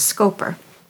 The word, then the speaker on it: Schaper: She spells her last name S C H A P E R. I think the pronunciation is Scoper.